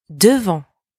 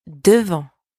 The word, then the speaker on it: devant: Next one, devant. In front of. Devant. Devant. Same here, don't pronounce the T at the end.